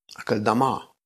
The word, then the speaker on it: Akeldama: Um, tak, i no, później dowiaduję się o tym, że do pole, na którym się powiesił, e, zostaje nazwane Akeldama. Akeldama. Jest akcent na ostatnią sylabę. Akeldama to tak jak nazwa zespołu rockowego brzmi.